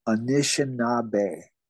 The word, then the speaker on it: Anishinaabe: The Anishinaabe, is did I say it right? Anishinaabe? – Anishinaabe. – Anishinaabe. – Yep.